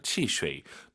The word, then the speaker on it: 汽水: 삼성의 마지막 글자만 올리지 마시고요. 치쉐이. 너무나도 좋은 발음을 가지고 계신 왕 선생님께서 발음하고 계시잖아요.